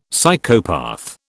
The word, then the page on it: Psychopath: British English